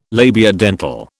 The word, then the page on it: labiodental: British English